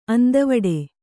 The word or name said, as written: ಅಂದವಿಡು